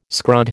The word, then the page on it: scrod: American English